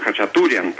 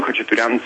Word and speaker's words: Khachaturian: Khachaturian en ruso, aquí cambian acento en español, como ustedes no ponen acento en la última sílaba cuando la palabra termina en N, dicen Khachaturian, pero realmente en ruso dicen Khachaturian.